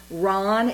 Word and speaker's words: Rahn: And my question is, from looking at your website, as I mentioned, Greg, and I hope I'm pronouncing Ron, is it R-A-H-N? – Yes.